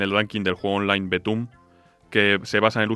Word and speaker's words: Btooom: Sí, se escribe B-T-O-O-O-M con esta exclamación. – Al final, sí. – O sea, se pronuncia Betum, yo siempre que lo he oído me han dicho Betum.